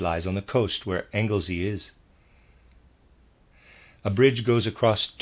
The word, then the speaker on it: Anglesey: Reader's aside, it's spelled A-N-G-L-E-S-E-Y, Anglesey, I'm going to say, end of reader's aside.